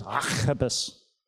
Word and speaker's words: Archippus: Actually, the correct way to say that word is Archippus. Don't you love that? But I'll just say Archippus. It's a bit nicer.